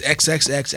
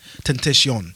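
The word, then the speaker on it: XXXTentacion: But how it's actually pronounced is XXX as in like, you know, nudity. – Okay. Nudity. – And then temptation with the Italian version of temptation. Tentacion.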